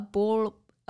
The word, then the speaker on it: bauble: Is it ball, ball? – No, a bauble. – Spell it for B-A-U-B-L-E. Like a Christmas bauble. Yeah. Language barriers, people, language barriers. Did I spell it right? Yeah, B-A-U-B.